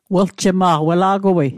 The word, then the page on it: weljema'q: Mi'gmaq